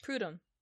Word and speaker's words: Prudhomme: Prudhomme.